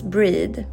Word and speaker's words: Brigid: Och Imbolc är en fertilitetsfest tillägnad gudinnan Brigid, vilket uttalas Breed.